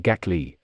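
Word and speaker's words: Gackle: Gackel, whose name is pronounced Gackley,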